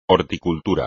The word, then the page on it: horticultura: espagnol